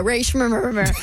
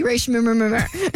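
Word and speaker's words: Rae Sremmurd: Yeah, Ray Shrimmer. – Yeah, go with that. That's better. – It's spelled S-R-E-M-M-U-R-D, Ray Shrimmer.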